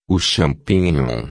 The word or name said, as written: champinhom